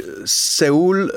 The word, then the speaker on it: Σεούλ: ναι, η Σεούλ ε, βασικά πρέπει να προφέρεται κάπως σαν soul.